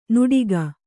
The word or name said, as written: ನುಡಿಗ